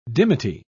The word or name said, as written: dimity